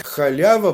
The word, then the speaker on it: халява: Ближе по-русски говорить халява, потому что это оригинальное произношение слова.